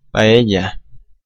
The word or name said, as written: paella